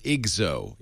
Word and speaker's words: IGZO: And it's pronounced IGZO. Is that right? – Yeah.